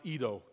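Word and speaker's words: Edo (οἶδα): And then he says, and I know that looks like a strange word, oida, but it's pronounced Edo. Don't ask me why, but the Greek word is Edo, and it means I don't even recognize you.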